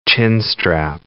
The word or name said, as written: chinstrap penguin